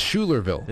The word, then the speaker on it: Schuylerville: It's Schuylerville. – It's Schuylerville. The guy, Fred Drum, that disappeared. My father knew him. – But wait, it is Schuylerville? – It's Schuylerville, yeah. It's after Philip Schuyler.